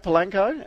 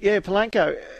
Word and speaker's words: Polanco: Polacano, or how do we say it? Polanco. And Polanco.